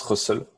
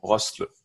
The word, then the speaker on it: Rustls: Dans, dans, le papier, il y a marqué Pronounced Russell. Russell.